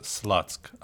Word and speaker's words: Слуцк: Потому что по-английски город пишется S-L-U-T-S к. Слуцк читается Слацк, а Слад — это по-английски, я прошу прощения за мой русский, это шлюха.